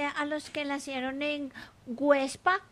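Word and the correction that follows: Güepsa: Perdón, Huepsa. Websa. ¿Ah? Con diéresis.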